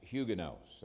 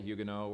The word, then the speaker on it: Huguenot: Huguenots. And I mentioned on Friday that you can pronounce it either way, Huguenot, or the English pronunciation is Huguenot.